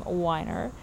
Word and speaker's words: Weiner: Carmeet was kind enough to let me know that Jennifer Weiner pronounces her last name Weiner. Carmeet is German. She lives in Germany. And, of course, you know, in German, and Weiner, I think, seems like a fairly German name. It's E-I. If you have E-I, you pronounce the second vowel in the vowel combo. So I know that.